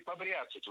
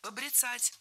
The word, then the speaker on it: побряцать: Побряцать? Неверно. Правильно? Побрицать. Это слово имеет настолько непривычное ударение, что большинство людей не слышали и не представляют, что правильно? Побрицать, брицать. Ударение в этом глаголе и в однокоренных глаголах ставится за корнем.